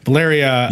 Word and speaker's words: Valeria: like that's why I picked Valera. I can never say her name correctly. – I think it's Valeria. – Valeria.